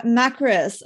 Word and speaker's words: Makris: And today I'm joined by my guest, Sarah Makris.